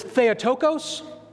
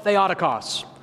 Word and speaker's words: Theotokos: So some people refer to this word as Theotokos. Some call it Theotokos. So however you prefer it, potatoes, potatoes, you can have it.